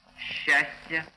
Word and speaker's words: счастье: Группы ЗЧ, СЧ произносятся как ща. Извозчик, грузчик, счастье, подписчик, брусчатка.